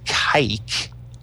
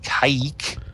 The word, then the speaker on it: Kazik: I think it was pronounced Kaik. It was K-A-Z-I-K, but pronounced Kaik,